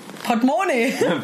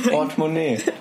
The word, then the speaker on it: Portemonnaie: Seit wann betont man die erste Silbe? Portemonnaie, das ist französisch. – Portemonnaie. Portemonnaie.